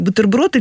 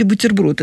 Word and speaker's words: бутерброд: Вот, там я иногда спрашиваю студентов, я говорю, как вы говорите, бутерброд или бутерброд? Это недавно заимствованное слово, но понятно, что адаптация, если адаптация уже к языку, то правильно говорить бутерброд, мягкий. Вот. А если еще не очень адаптировано, то бутерброд.